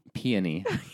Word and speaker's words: peony: Is it pony? – Peony. – Pony? – It's a flower, Julian. – Okay. It could have been a silent E.